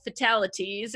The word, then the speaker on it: Fatalateas: Fatalities, and that's spelled TEAS, because I get together with friends over tea to talk about true crime, and that's at Fatalities on all the socials and on Apple Podcasts.